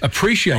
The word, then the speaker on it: appreciate: Appreciate, you're saying? – I think you say it with an SH sound.